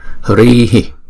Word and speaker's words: Hri: Nhưng trong câu chú thì chúng ta không có cần đọc chữ Hi đó. Chúng ta chỉ đọc là Hri. Chúng giống như cái hơi chúng ta hà ra thôi.